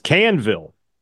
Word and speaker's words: Keown: It's Canville from the other day. Canville, which is incredible considering it's spelled K-E-O-W-N. That's can somehow.